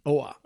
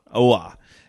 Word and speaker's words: Oa: Oa. – Oa.